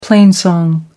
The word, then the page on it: plainsong: American English